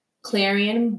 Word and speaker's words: Clarion: You can reach me at stylist Clarion B. That's stylist Clarion B. I cannot think of it. The underscore. That's what it is. Stylist Clarion B underscore. My name is spelled C-L-A-R-I-O-N-B. You can also reach me at Clarion, C-L-A-R-I-O-N, D-E-N-I-S-H-A .com.